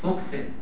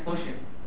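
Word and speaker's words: fuchsin: هو النطق الحقيقي ليها فوشن، لكن هتسمعها في المعمل عندنا فوكسن، تعودوا على نطقها كده مش مشكله. نقول فوكسن زي ايه؟ لما تبقى في بلد اعمل زي اهل البلد بيعملوا، مش كده؟ لما تبقى عندنا قول فوكسن. لو نطقها الحقيقي فوشن لان لونها فوشن.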